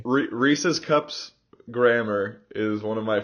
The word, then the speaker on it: Reese's: pronunciation, because it's Reese apostrophe S. They're his cups. It's Reese's cups.